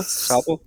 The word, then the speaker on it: Sabro: Habe ich es richtig ausgesprochen? Sabro? – Sabro. – Ja.